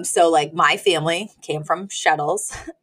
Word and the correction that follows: shtetl: Shtetl. Um, Shtetl. Shtetl. it rhymes with like kettle.